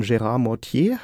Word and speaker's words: Gérard Mortier: Du hast Jura studiert, hast ein Staatsexamen da auch gemacht und bist dann aber ins künstlerische Fach gewechselt als Assistent von Gérard Mortier.